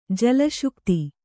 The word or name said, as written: ಜಲಶುಕ್ತಿ